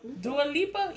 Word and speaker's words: Dua Lipa: That's how you say her name. Dua Lupa. Dua Lupa. – Lipa. – Dua Lipa. Yeah, Dua Lipa. You said Dua Lupa.